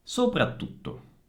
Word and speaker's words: soprattutto: Nuovamente, anche in questo caso, per fare la doppia bisogna fare una piccola pausa prima della T. Abbiamo 4 T in tutto. Soprattutto.